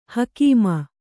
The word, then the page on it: ಹುಕುಮು: hukumu